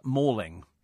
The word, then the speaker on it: Malling: Malling in Kent is pronounced Mauling.